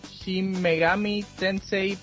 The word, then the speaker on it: Shin Megami Tensei: Entonces, para el PSP sale esta semana Shin Megami Tensei Persona.